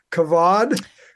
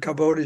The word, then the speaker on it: Kavod: For the first thing, you pronounced it correctly. Most people pronounce it Kavod, but it should have an E at the end in the English transliteration.